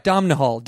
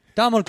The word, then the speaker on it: Domhnall: Domhnall. It's spelled D-O-M-H-N-A-L. – You just call him Dom, then. – Donald Gleason. – Domhnall Gleason.